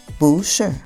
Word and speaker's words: 不是: Remember from the previous episode, we talked about the Mandarin Sandy, when the fourth tone of bu is followed by another fourth tone character,Then the fourth tone of bu becomes a second tone, bu. So even though the pinyin shows fourth tone in bu, shi, but the actual pronunciation is bu, shi.